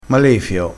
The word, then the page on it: malefio: dialettale